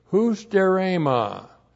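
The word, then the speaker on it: hustarema (ὑστέρημα): H-U-S-T-E-R, long E-M-A, hustarema. And hustarema means need or want or deficiency.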